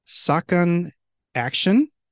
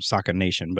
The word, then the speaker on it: Sakanaction: I keep wanting to call them Sakan Nation, but that's not what it is.